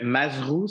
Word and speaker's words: Mazrou: Oui, fait que Mazrou, ça s'écrit M-A-Z-R-O-U. Fait que le R après le Z, parce que ça, c'est l'histoire de ma vie que déplaît mon nom de famille.